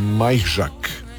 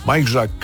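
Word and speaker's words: Majchrzak: Bosch uzeo poljski tenisač Kamil Majžak, tako ćemo ga izgovoriti, najbliže točnome. Majžak, 30 mu je godina, i u današnjem finalu na travi u nizozemskom Hertog M.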